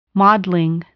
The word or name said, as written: modeling